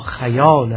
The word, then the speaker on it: خیال: تو گویی که آینه دل من هیچ چیز دیگری در آن متجلی نمی شود به جز خیال خیال که تلفظ دقیقش در فرهنگ نامه ها خیال